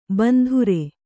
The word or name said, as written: ಬಿಂದಾರಿ